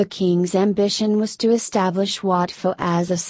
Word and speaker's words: Wat Pho: Commonly known as Wat Pho, pronounced poor.